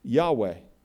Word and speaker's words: Yahweh: Yahweh.